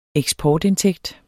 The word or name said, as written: eksportindtægt